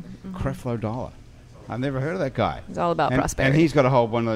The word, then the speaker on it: Creflo: Creflo.